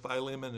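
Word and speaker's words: Philémon: And in French, the word Philemon is pronounced Philemon. And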